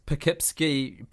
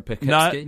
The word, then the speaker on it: Poughkeepsie: No, she instead resorting to her Poughkeepsie pudding pots.